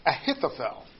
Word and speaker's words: Ahithophel: But he prays a prayer about Ahithophel. Said that wrong. Ahithophel. There we go. There we go. That sounds better.